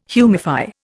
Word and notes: humify: Indian

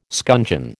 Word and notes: scuncheon: American English

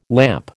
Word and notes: lamp: American English

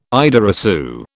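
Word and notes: idée reçue: British English

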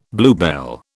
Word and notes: bluebell: British English

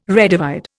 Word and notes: redivide: Indian

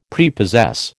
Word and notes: Prepossess: American English

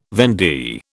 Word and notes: vendee: British English

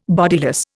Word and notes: bodiless: Indian

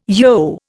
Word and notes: yogh: Indian

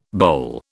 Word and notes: boulle: British English

